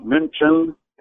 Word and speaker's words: München: Munich in German is pronounced München, and it means the place of the monks because it was a big religious center.